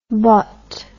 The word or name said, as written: botte